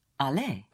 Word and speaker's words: allez: Most verbs will end in ER. More than 90% of all verbs end in ER. Pronounced A. Like to go would be Allez.